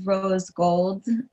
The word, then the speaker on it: R0zegld: Everything is Rose Gold, which is R0zegld.